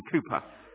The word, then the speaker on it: Cowper: I don't know if you've heard the story of the English poet and hymn writer William Cowper, William Cooper, as it's pronounced, but C-O-W-P-E-R.